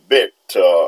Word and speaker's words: Beth: B-E-T-H, c'est comme ça, il est écrit en anglais, Beth, et vous prononcez Beth.